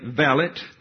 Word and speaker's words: valet: In 18th century England, a young duke had a valet. I've learned that's how you pronounce it.